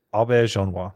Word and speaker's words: Auberjonois: Auberjonois. – You got it? – Yeah. – Say it one more time. – Rene Auberjonois.